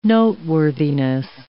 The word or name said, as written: noteworthiness